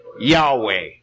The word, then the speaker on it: Yahweh: Most accurately, it would be pronounced Yahweh.